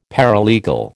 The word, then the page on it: paralegal: American English